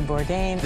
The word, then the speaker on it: Bourdain: I think I've pronounced that name Bourdain. – Correctly. So there's a documentary on him. It's called Roadrunner. – A film about Anthony Bourdain. – Chef Anthony Bourdain. – Anthony Bourdain. – Anthony Bourdain.